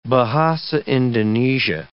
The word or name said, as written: Bahasa Indonesia